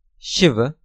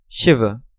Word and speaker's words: Shiva: Next row, Hindi-Sanskrit word, Shiva, a deity. Usual transliteration, S-H-I-V-A. Sanskrit pronunciation, Shiva. Hindi pronunciation, Shiv. Foreigner's pronunciation, Shiva. Next row, Hindi-Sanskrit word, Varun, a deity. Usual transliteration, V-A-R-U-N-A.